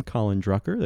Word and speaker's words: Colin Drucker: Colin Drucker. That's C-O-L-I-N-D-R-U-C-K-E-R.